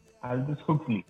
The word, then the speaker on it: Aldous Huxley: Eh, Soma eh, es, eh, es una droga que siempre pronuncio fatal el nombre del, del escritor. Axel. Aldus eh, Aldus Aldous Huxley. eso.